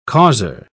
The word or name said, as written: causer